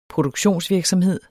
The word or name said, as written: produktionsvirksomhed